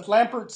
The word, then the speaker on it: Lampert: Am I saying it right? Yeah. L-A-M-P-E-R-T. Lampert.